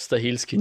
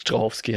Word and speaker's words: Stahelski: Und weil ich vorher falsch gesagt habe, Chad Stahelski, nicht Strahovski, heißt der zweite Typ aus der John Wick Gang, der ja auch mittlerweile Solo-Filme macht, glaube ich.